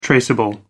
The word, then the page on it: traceable: American English